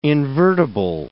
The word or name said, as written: invertible